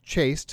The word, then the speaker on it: chaste: And as you point out in the entry in the book, chaste, C-H-A-S-T-E, having the same pronunciation as C-H-A-S-E-D, you can make an amusing mistake there or a very tired pun.